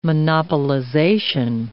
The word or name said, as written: monopolization